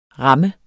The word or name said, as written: ram